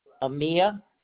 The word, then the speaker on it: Amia: Amia? – Yes, ma'am. – How do you spell that, please? – A-M-I-A. – A-M-I-A.